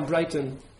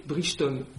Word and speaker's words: Brighton: c'est quelques kilomètres de bord de côte vers Brighton, que la mère prononce Bridgeton. Elle prononce Bridgeton. C'est écrit B-R-I-C-H.